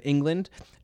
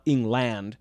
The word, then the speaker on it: England: What's interesting about the name England is that it's kind of easy to forget the word land is in England just because of the pronunciation shift of that last vowel, how that A in land has become a schwa, just like a sound. It's not England, we say England.